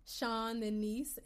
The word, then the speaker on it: Seanniece: That's Seanniece, Seanniece, because I am Sean's niece, and my last name is Bamiro, Bamiro.